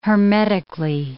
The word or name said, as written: hermetically